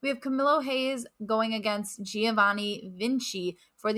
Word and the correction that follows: Carmelo Hayes: there is an R in that. You said Camilo. – Oh, I'm sorry. I thought I said the name correctly and I went way past it. Carmelo Hayes.